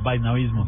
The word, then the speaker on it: vaisnavismo: Lo he tenido problemas para pronunciarlo. Está perfecto, vaisnavismo.